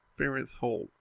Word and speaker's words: Farenthold: Yeah, I looked up how to say his name because I wanted to get it right. Farenthold. Old. Farenthold.